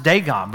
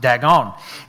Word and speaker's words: Dagon: Now, it's in Hebrew, it is pronounced Dagon, but in Smyrna, it's pronounced Dagon.